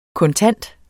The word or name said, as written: kontant